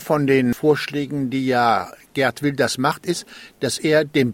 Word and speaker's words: Geert Wilders: Das war auch, äh, oder äh, von dem sagt Gerd Wilders, so spricht man ihn auf Niederländisch, aber wir bleiben bei Gerd, glaube ich.